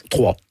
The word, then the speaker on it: Troyes: Vous commencez à jouer, vous êtes né à Troyes, En France, comment on prononce ? – Trois. – Trois, ben voilà, ça s'écrit YES.